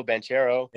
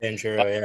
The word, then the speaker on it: Banchero: I'm going to say his name, but Paolo Banchero. – Banchero, yeah.